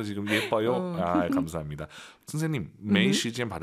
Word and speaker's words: 没时间: 저 지금 발음 틀릴 뻔했어요, 여러분. 没时间, 没时间 다 알아들어요. 틀린 발음이에요. 没 아니죠? – 没 왜 올라가야 돼요? 아아 没时, 没时间 이성이 길어야 예뻐요.